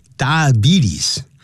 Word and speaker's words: diabetes: For today, we have this lesson 212, and you can see the title that has to do with some kind of disease known as diabetes. Diabetes, 这样发音，不要念 diabetes, B-T-S, 多发 E 的长音。但如果说他是个糖尿病患者，我们就要说 He is a diabetic, 那是一个可数的名词。 There are diabetics, D-I-A-B-E-T-I-C, 复数加个 S。好，我们现在就一起来看这里面文章的内容。 In the last decade, Incidences of diabetes in Asia's youth has skyrocketed.